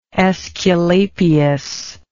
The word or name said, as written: Aesculapius